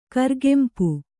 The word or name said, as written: ಕೌರುಗಂಪು